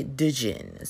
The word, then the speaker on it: Djinn: Now, book four, right, Oracle's Moon, we've got the, now, okay, it's spelled D-J-I-N-N, right? I say Dijin. So you might be like, I said it's just Jean or Jean, like genie.